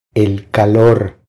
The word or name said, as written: El calor